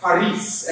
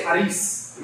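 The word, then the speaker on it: Haris (χάρις): It's spelled with a K, but it's pronounced Haris. Everybody say Haris.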